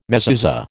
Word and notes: mezuzah: American English

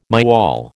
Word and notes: myall: American English

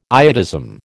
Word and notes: iodism: American English

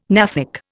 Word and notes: gnathic: Indian

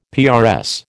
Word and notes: PRS: American English

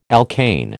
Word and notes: alkane: American English

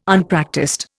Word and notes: Unpractised: Indian

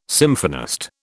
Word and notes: symphonist: British English

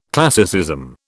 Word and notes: classicism: British English

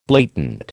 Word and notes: blatant: American English

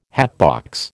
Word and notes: hatbox: American English

